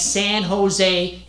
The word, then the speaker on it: San Jose: I says, dear, over in Spain, they don't pronounce the J is silent, like that San Jose and San Juan.